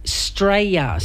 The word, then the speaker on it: Straya: and that is Straya, spelt S-T-R-A-Y-A. It's how most of us pronounce Australia, is simply Straya.